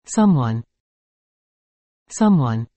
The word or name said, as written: someone